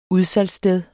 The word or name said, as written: UDSALGSSTED